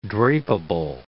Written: drapeable

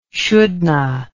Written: shouldna

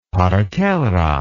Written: partera

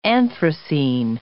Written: anthracene

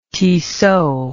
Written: Tissot

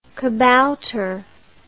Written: kabouter

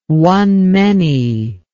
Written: one-many

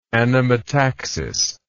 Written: anemotaxis

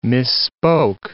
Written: misspoke

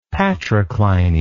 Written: patricliny

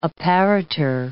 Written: apparitor

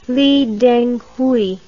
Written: lidenghui

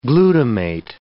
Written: glutamate

